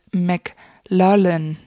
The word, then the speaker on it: McLaughlin: Ich hab's gehört, Dominic McLaughlin. – Oh, McLaughlin, okay, dann haben wir es falsch gesagt.